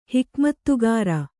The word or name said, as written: ಹಿಕಮತ್ತುಗಾರ